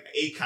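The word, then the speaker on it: açaí: It sounds, I always pronounce it like acai. I thought it was like acai.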